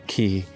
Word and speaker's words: quay: pushing into the open quay, or key, or however else you want to pronounce it. – Quay. – Remember that again? – The quay.